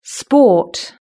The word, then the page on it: sport: British English